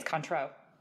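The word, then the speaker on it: Cointreau: Renee's taught me how to pronounce this recently. It's Contro.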